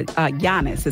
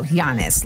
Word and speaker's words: Giannis: Giannis is how you pronounce his first name. – There we go. Giannis.